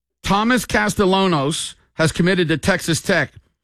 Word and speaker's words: Castellanos: And I remember him because, you know, Nick Castellanos is in Major League Baseball. That's how it's pronounced. – Right. – But he pronounces the L's. It's Castellanos. – Castellanos, yeah.